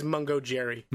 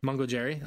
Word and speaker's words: Mungo Jerry: Mon- I, I, think it is Mungo Jerry. – Mungo Jerry.